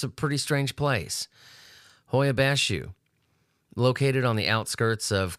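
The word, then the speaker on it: Hoia Baciu: We're going to go to Transylvania, to a place called Hoia Bashu, which that is how it's pronounced.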